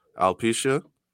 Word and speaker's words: alopecia: Alopecia.